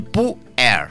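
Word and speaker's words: pu-erh: El puer. Así se pronuncia.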